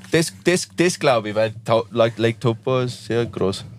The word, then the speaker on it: Taupo: Ja, Taupo. Ja. – Taupo. – Taupo ist actually a Maori word. Taupo. Ja.